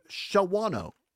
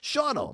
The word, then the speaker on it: Shawano: I'm going to be on the road this weekend, Zomers, going up to Shawano. That's not how you say it. Shawano.